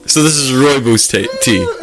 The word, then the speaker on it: rooibos: But it's, rooibos. It's spelled R-O-O-I-B-O-S. Rooibos. – Rhombus. – Rooibos. – Rooibus? – Rooibus. – Rooibus. – Actually, don't say bus, because that's the wrong pronunciation.